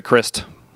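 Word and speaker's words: Krist: Or like Chris Novoselic used to do from Nirvana, and just pronounce it Christ, but with a clear T at the end.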